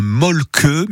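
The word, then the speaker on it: Mölkky: Un molke, mais ça s'écrit molki ou mölki.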